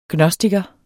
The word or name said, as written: gnostiker